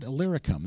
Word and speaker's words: Illyricum: Illyricum, that's so easy.